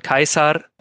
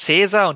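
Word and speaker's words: Caesar: Und äh, da sehen wir dann, dass das ist immer so das klassische Beispiel, also dass der Name Kaisar, also wir sagen ja auf Deutsch Cäsar, dass eben dieser Name Kaisar auf Griechisch mit dem Buchstaben Kappa geschrieben wird, von dem wir halt definitiv wissen, dass er wie ein K ausgesprochen wird, also wie unser K. Und da sehen wir dann, okay, wir sagen jetzt Cäsar und die Italiener sagen, äh, also sprechen es mit Ch aus, also Cesare. Aber wir wissen, die Römer haben definitiv zur Zeit Caesars, ähm, haben sie halt das mit einem K ausgesprochen. Und solche Indizien hat man also auch,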